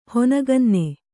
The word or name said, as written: ಹೊನಗನ್ನೆ